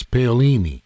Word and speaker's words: Paolini: I believe it's Paolini. – Paolini. – Paolini. – Paolini. Ah, oui. – Paolini. – And that's Paolini.